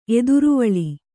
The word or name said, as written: ಎದುರುವಳಿ